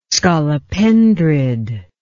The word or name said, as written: scolopendrid